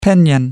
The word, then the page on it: pinion: American English